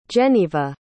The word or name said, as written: jenneva